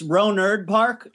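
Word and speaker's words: Rohnert Park: I also it's Ronard Park. It's the T is actually pronounced like a D out here. I don't know if that's just how people pronounce it out here or not.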